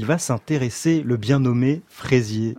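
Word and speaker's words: Frézier: Alors son nom, ça ne s'écrit pas exactement comme les Fraisiers que l'on connaît, hein, c'est Fraisier, F-R-E-Z-I-E-R, mais il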